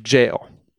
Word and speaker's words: Gaol: And the ladies of Fairhaven, formerly of Gorse Hall, were brought in to identify Mark Wilde at Knutsford Jail. – Knutsford? – Yeah, and I stuttered on the word jail there because it's spelled the old-timey English way. Yeah, it's Knutsford Gaol.